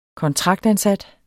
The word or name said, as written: kontraktansat